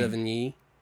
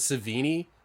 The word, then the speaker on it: Sevigny: Sevigny. I think I said it right that time. – You said it better than I could say it. – Yeah. I've been practicing because I'm like, I don't want to say it wrong. I always thought it was Savini, but that's totally wrong.